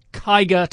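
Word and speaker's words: Kiger: Anyway, K-I-G-E-R. I'm told it's the Renault Kiga to rhyme with tiger.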